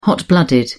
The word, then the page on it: hot-blooded: British English